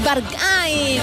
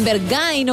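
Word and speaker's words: Berghain: Es Bargain, o Bergain, o como cada uno lo quiera pronunciar, pero efecadas.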